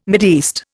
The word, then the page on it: Mideast: Indian